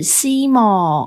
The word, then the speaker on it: SYMO: 接下来这位是 S Y M O，然后他的括号写说：“ 猜你不知道怎么念，所以是 Simo 哦，Simo Simo S Y M O Simo 看得懂哦，看得懂哦，Simo Simo。